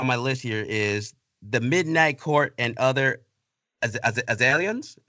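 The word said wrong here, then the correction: aislings: I'll be honest, I had no idea how to pronounce this one at first. – Yes. – It's Irish, so it's The Midnight Court and Other Ashlins. So again, I would never have thought it was Ashlins, but yeah, it's Ashlins, that final word.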